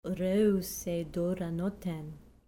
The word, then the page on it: reusei duraanothen: grec